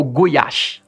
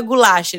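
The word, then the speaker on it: gulyás: É o goiás. – Goiás, é isso. Não é goulash. Eles vão perceber aqui se você quer realmente local, você tem que pedir um goiás. – Goiás, exato.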